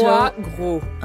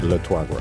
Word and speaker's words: Troisgros: Trois Gros. – I'm so... – Trois Gros. – Like, if you can get a little bit of the... in the throat, but it's very hard.